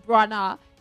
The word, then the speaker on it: Branagh: Last week we slaughtered his surname. It's Branagh.